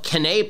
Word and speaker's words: Canae: It's called Canae Pro Gear. It's spelled CanaeProGear. Canae Pro Gear, based off the Battle of Cannae. And it's named after that battle.